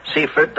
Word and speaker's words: Seifert: Seifert, Dr. Carl Seifert, Jr., who received his Ph.D.